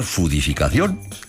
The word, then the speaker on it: foodificación: Puedo escribir foodificación y pronunciar fudificación.